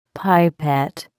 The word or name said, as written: pipette